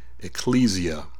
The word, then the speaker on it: ekklesia: The word church comes from a Greek word, ekklesia. Some people may pronounce it ecclesia, and it means the called out ones, the separated ones.